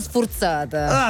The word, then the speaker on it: sfurzata: Scrive: ingegnosi i Muse, la band, ma come diciamo a Taranto, sanno sfurzata. – Ah, sanno sfurzata, si pronuncia. – Sfurzata.